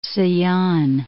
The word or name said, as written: Sayan Mountains